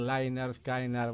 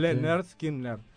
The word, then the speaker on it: Lynyrd Skynyrd: Y bueno, ahí pusieron el pronouncer porque cómo se pronunciaba, porque si escuchás la I es Y, entonces muchos pueden decir Lynyrd Skynyrd. Bueno, Mire usted. – Lehner Skinner.